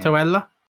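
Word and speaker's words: Santaolalla: I don't know how to say his last Santuella? – name. – Santuella. – Yep, that's his name.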